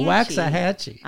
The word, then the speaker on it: Waxahachie: Saying you were going to Waxahachie. – Yes. – Did I say that right? – I think so.